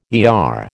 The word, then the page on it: ER: American English